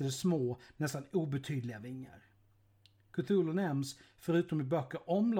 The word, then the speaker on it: Cthulhu: Namnet ska, enligt vissa, uttalas Cthulhu Enligt ett av de brev som Lovecraft skrev är det dock så här. Själva ljudet, så som mänskliga stämband kan imitera det eller mänskliga bokstäver skriva det, blir det ungefär kluvlo, där det första uttalas gutturalt och väldigt tjockt.